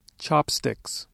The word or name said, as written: chopsticks